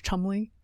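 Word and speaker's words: Cholmondeley: Lady Rose Chumley. Is that right? – Oh, is that yes. It's said. It's spelled very differently than that.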